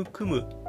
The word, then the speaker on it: 育む: そこで、あの、育むという言葉、えー、教育の育、育てるっていう字にひらがなで無と書いて、育むという読み方、